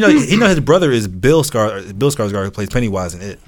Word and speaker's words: Skarsgård: But, number one is Stellan Skarsgård. – Skarsgård, yeah.